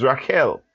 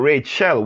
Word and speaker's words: Rachel: Yes, her name is Rachel, not Rachel. We pronounce it Rachel in English, but the name actually is Rachel.